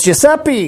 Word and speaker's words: Giuseppe: It's Giuseppe.